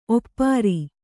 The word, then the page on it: ಒಪ್ಪಾರು: kannada